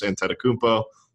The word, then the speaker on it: Antetokounmpo: and I think you're seeing guys like Giannis Antetokounmpo on the Bucks.